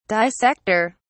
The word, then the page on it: dissector: British English